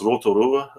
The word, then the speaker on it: Rotorua: Is that what we call it, Rotorua? – Yeah, well, it's pronounced Rotorua, which means the second lake.